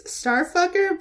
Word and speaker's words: Strfkr: So it's Starfucker, but it's spelled Strfkr.